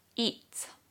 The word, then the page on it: EAT: British English